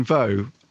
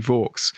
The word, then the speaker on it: Vaux: Well, the real life Anne Vaux, actually, we think she was called Anne Vaux, rhyming with forks. But of course, the reason we couldn't call her Anne Vaux is because people would mix up Vaux and forks on screen. So we think it's the way that the family would pronounce it now would actually be Vaux.